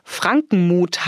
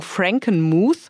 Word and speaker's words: Frankenmuth: Frankenmuth heißt der, so wird man es auf Deutsch aussprechen. Die Leute, die da leben, sagen Frankenmuth.